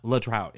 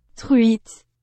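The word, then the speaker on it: truite: But I looked it up and it's not le trout. It's this. – Tweet. – Oh, tweet. It's tweet. – It sounds like tweet, but it's like tweet, tweet.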